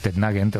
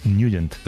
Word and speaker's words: Nugent: Hombre, siempre lo hemos llamado en el barrio Tednagent o Tednagent. – Claro, yo era de Tednagent. – Pero la pronunciación correcta es Nugent. – Te fuiste a Washington y descubriste que era Nugent.